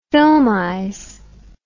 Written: filmize

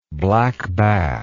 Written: black bass

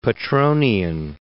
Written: Petronian

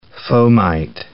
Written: fomite

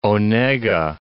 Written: Onega, Lake

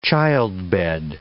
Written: childbed

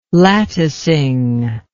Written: latticing

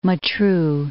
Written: Matruh

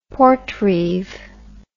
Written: portreeve

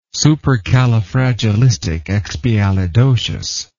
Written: supercalifragilisticexpialidocious

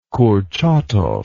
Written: kurchatov

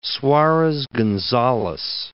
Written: Suarez Gonzalez

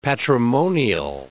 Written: patrimonial

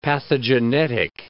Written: pathogenetic

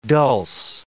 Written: dulse

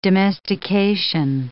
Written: domestication

